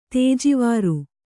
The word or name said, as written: ತೇಜಿವಾರು